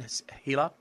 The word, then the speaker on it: HeLa: The, if I'm saying it wrong, the HeLa, H-E-L-A, is that how you say that stem line? HeLa? – HeLa.